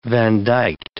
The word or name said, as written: vandyked